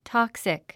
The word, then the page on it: toxic: tάksik; トクスィク